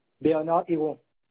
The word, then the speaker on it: Bernard Héron: Bernard Héron. – D'accord. Ça s'écrit comme ça se prononce? – C'est bien cela.